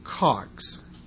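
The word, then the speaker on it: chag: As I had mentioned on the first day of the Feast of Tabernacles, these festivals are called CHAGS, pronounced COGS. You know, it's that favorite Hebrew guttural sound that we're all familiar with, COGS.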